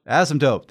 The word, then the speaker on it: asymptote: Asymptote? – Asymptote, thank you.